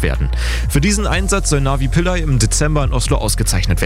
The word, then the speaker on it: Navi Pillay: Die Friedensnobelpreisträgerin aus Südafrika heißt mit vollem Namen Navanita Mpilai, ausgesprochen wird sie Navi Pillay.